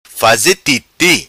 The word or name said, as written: Titi' Faze